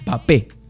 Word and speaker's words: Mbappé: Mbappé, hoy día, como me dice Fernando Carvalho, hay que pronunciarlo bien, es Mbappé. Kylian Mbappé, así se pronuncia. Bappé. No se pronuncia la M, es muda en francés.